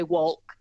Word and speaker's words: walk: We still have the L in the spelling, don't we? But none of us would say walk and talk. None of us pronounce the L there.